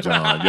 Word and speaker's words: Jon: But on social media, at John Glass _ J-O-N, no H's.